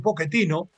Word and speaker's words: Pochettino: ¿Qué dice Mauricio Pochettino, porque así se debe pronunciar porque es en italiano, de este partido frente a México?